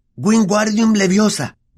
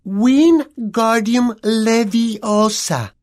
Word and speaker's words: Wingardium Leviosa: «Es Wingardium Leviosa. Pronuncia gar más claro y más largo».